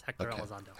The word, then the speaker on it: Hector Elizondo: Yes, Hector Elizondo.